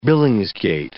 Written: billingsgate

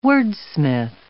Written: wordsmith

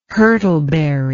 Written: hurtleberry

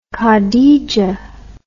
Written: Khadija

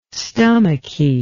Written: stomachy

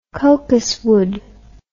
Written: cocuswood

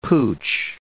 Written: pooch

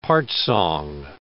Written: part-song

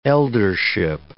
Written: eldership

